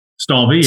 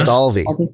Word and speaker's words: Stalvey: Is that am I saying that right? Stalvey. – Stalvey. Yes. Stalvey.